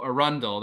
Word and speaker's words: Arundel: So Arundel, that's how it's pronounced. I thought it was like Arundel.